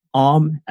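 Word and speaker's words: Aom: So my name is Ohm. It's kind of hard to pronounce, but that's how you pronounce it.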